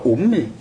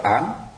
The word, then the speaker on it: úm: người ta đọc là úm. Ví dụ úm bà la gì đó, quý vị nhớ không? Úm này úm kia đó, úm này úm kia, chứ không phải là om.